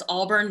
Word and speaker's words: Aubern: I am on Etsy, Instagram, and Facebook as Aubern Mason Artist. And my name is spelled kind of funny. It's A-U-B-E-R-N, Mason, comma, artist.